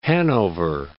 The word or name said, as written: Hanover Park